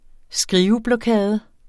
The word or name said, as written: skriveblokade